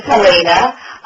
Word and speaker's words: Helene: My name is Helena, even though it's spelled Helene.